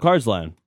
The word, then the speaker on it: Carsland: Brennan from Carsland. Am I saying that right? – Carsland. – Yeah. – Like Cars Land. – Yeah, like the movie Cars.